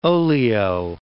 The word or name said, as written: oleo